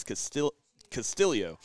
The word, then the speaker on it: Castillo: I also wanted to bring up John Murphy in his match versus Ricky Castillo is how we learned how to say it, because he actually has Filipino background in his family. So even though it looks like Castillo or Castillo, it's Castillo.